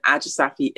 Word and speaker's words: Ajisafi: My Instagram is Chrissy Ajisafi, which is Chrissy with two S's, C-H-R-I-S-S-Y. And then Ajisafi is my surname. It's like Aji Safe. It's spelled A-J-I and then Safe, S-A-F-E.